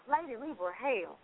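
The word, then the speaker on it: Lady Libra Hell: Lady Libra is one word, LadyLibra, space hell, HELL, and it's pronounced Lady Libra, hell.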